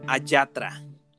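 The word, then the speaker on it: Allatra: Se escribe con doble L y se pronuncia Ayatra.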